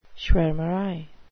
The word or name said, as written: schwarmerei